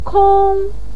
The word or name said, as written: kong1